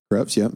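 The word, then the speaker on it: crêpes: And did you make crepes then? – Crepes, yep. – Did I say it right? – You did. I'm glad you didn't say crepes.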